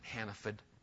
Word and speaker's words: Hannaford: By the way, Pat, we pronounce it Hannaford, just so you know.